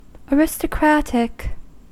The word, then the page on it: aristocratic: American English